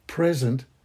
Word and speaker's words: present: There are two aspects to being present, being there, and then the word present, pronounced present, is the second aspect.